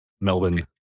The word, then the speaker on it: Melbourne: So is it Melbourne? Like, do you not pronounce the R or? – Yeah, it's Australia. We pronounce the bare minimum of everything. It's just okay. Melbourne. M-E-L-B-N. Melbourne.